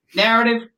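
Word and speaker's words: Knarrative: Narrative with a K. The K is silent like knowledge.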